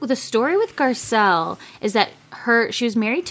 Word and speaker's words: Garcelle: Let's talk about Garcella. So Garcella. – No A. – Oh, Garcelle. Sorry.